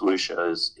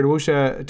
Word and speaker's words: wuxia: I've usually seen it in print, so with my due deference to people who actually speak Chinese, wuxia is I'm pretty sure how it's pronounced.